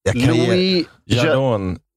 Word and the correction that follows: Jadot: Louis Jadot.